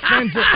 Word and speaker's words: FanDuel: It's FanDuel. Oh, somebody said I'm pronouncing it like Cool Whip. This is the Family Guy Cool Whip, and I don't know. Somebody's going D-E-double-L, duel.